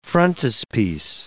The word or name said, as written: frontispiece